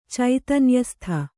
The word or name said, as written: ಚೈತನ್ಯಸ್ಥ